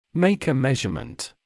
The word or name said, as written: make a measurement